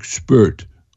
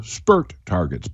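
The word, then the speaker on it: Spüt: They are called, I'm going to phonetically say them, but also spell it, SPUT targets, S-P-U, but with an umlaut over it. Now, you may say, what's an umlaut? That sounds weird. That's where it's got two dots over the U, okay? So it's like German, and it would be almost be pronounced like spurt. Okay, so spurt targets.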